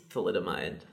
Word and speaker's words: thalidomide: How did you say it's pronounced? – I think it's thalidomide.